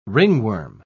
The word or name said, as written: ringworm